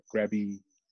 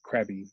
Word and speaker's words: Krabi: Like if you see like there's an area called Krabi in Thailand, and in English they spell it Krabi, but if they spell it in Thai language, it's more of a like a g, like a g sound, like Krabi. Instead of crabby, it's like crabby.